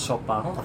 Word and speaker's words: Chopin: Kalau ikut nama bapaknya kan itu kan French makanya Chopin. Tapi kalau karena dia memang lahir dan besar di Polandia, orang Polandia tuh bacanya Chopin. – Oh, Chopin. – Nah, tapi kalau orang Perancis Chopin.